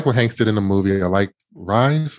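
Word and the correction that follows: Rhys: Is that how you say his name? Reese? – I think Reese. Matthew Reese. – Oh, good. That's even better. Reese. Matthew Reese, yeah.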